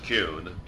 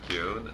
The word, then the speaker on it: Kuhn: It's pronounced Kuhn. – Excuse me. – And I wouldn't want people at Cooperstown to think I can't pronounce my own name.